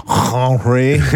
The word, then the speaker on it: Henri: How you say it with an accent? No, isn't that French? – It's French. – Oh, Henri. – Much better. Much better.